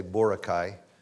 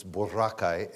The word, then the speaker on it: Boracay: Now, that doesn't mean much to you, but most Americans say Boracay. But in the Philippines, it's Boracay.